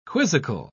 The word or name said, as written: quizzical